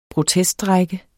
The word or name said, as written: proteststrejke